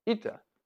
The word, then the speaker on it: eta: And in the literature, that letter is used, and it's not an H. It's to be pronounced eta. It's the Greek capital eta.